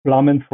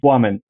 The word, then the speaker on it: Plamen: In Bulgaria, though, the real name, uh, the real way to pronounce it is Wamen. It comes out of Wamak, uh, which means a flame. So yeah, that's the way to pronounce it. Woman sounds like W instead of L but outside of Bulgaria, I'm just plumb.